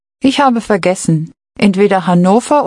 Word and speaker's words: Hannover: Hanover is pronounced Hannover.